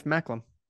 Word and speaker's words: Macklem: Tiff McCallum. How do you say his last name? – Tiff Macklem. – Macklem? – Yeah, you gotta say it like sure? A real it's not Anglophone.